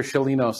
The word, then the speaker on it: Sciolino: Dr. Sciolino, say hello to everyone.